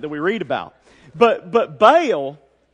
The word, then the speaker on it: Baal: When I took Hebrew at the University of Georgia, who knows if they got it right or not, but I think it's pronounced Baal. It's kind of like this little huffy puffy guttural thing that I can't do right.